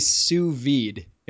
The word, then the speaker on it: sous vide: What is a sous vide? Am I pronouncing that right? – You are pronouncing correctly.